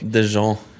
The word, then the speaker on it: Deschamps: Deschamps, som det, vi har lært at det uttalen, eller? – Ja, det for det den franske journalisten som vi snakket med tidligere I dag sa. Så da får vi tro han kan sitt eget språk.